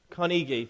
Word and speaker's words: Carnegie: I think that's how you pronounce his name. Carnegie, thank you.